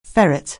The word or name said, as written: ferret